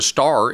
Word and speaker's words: Starr: And so The star is actually a Northumbrian name. It's S-T-A-R-R, not like up in the sky. And in Middle English, it's pronounced starry, and it means hawk.